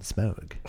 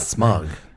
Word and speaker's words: Smaug: I liked when they called the dragon Smaug. And not Smug. They literally pronounce his name like Smaug.